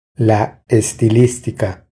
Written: La estilistica

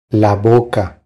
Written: La boca

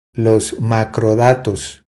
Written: Los macrodatos

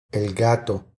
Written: el gato

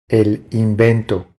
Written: El invento

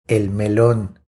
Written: El melon

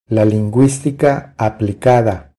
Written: La linguistica aplicada

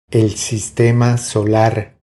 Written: El sistema solar

